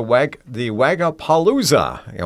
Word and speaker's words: Wagapalooza: We're going to be talking about the Wagapalooza.